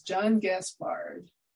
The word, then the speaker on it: John Gaspard: Coming to us from Minnesota, my guest today is John Gaspard.